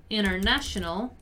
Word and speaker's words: international: Notice how I pronounce the word international without pronouncing the first T. I pronounce it as international, right? This is a very common pronunciation in American English. International, international, international. You don't really pronounce the first T.